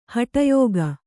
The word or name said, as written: ಹಟಯೋಗ